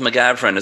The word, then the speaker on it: McGavran: And I was struck by this quote Donald McGavran.